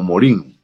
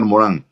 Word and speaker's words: Morin: Mucha gente lo conoce como Morin, porque es francés, pero el francés ese sonido IN al final se pronuncia como A, Edgar Morin.